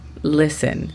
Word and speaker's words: listen: La pronunciación fonética de este verbo sería L-I-S-E-N. Listen. Tomando en cuenta que la pronunciación de la L la tenemos que hacer con la lengua tocando la parte posterior de nuestro paladar.